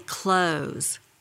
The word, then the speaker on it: clothes: or this word spelled C-L-O-T-H-E-S. How do you say that word? – I would say close. Is that one even up for grabs? – Well, so you say close as in close the door?